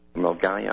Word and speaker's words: Milngavie: But the Sunday Mail can reveal 5 other secondaries: Bishop Briggs Academy, Douglas Academy in Milngavie, Milngavie actually, they pronounce it, Kirkintilloch High and Bishop Briggs Schools, St Ninian's High and Turnbull High will also get the scheme.